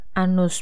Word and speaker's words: anus: Anus pun bahasa Inggrisnya sama, tapi pengucapannya anus. Anus.